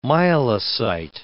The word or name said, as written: myelocyte